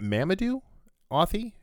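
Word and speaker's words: Mamoudou Athie: So I will say, um, is it Mamoudou? Athie? Is that how you say it? – I, I think so.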